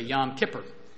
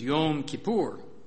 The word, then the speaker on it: Yom Kippur: A lot of times people say Yom Kippur. I think the Hebrew pronunciation is Yom Kippur.